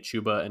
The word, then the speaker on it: Chuba: The other really polarizing running back that I found is Chuba Hubbard, and I've just now started pronouncing it Chuba and not Chuba because Chuba was just really a fun name to say.